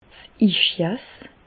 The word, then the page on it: 's Ischias: Alsatian; Bas-Rhin; Reichshoffen